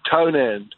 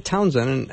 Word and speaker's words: Townend: Stuart Townend. – Townend. You know, it looked like a typo, but I said Townsend, and I said it incorrectly, so it's Townend.